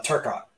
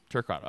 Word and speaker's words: Turcotte: Right, Can I say it right the first time or the second time? – Turcotte, yeah. – Turcotte, okay. Second time's a charm.